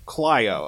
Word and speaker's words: Clio: Town of Is it Clio or Clio? – Clio, okay. Town of Clio, street-side flower boxes.